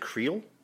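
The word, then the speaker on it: Krio: Creole? – It's Creole. K-R-I-O-L. Or I've seen two spellings. C-R-E-O-L-E.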